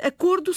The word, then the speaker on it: acordo: Houve derivações diferentes e, portanto, acordo segue a pronúncia do lobo, lobos. O O mantém-se fechado.